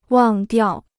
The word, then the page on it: 忘掉: wàng diào